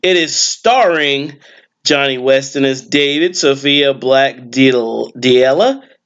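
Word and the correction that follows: D'Elia: as Jesse. – Delia. – Yes, there's an apostrophe type thing, so I didn't know how to say it quite right.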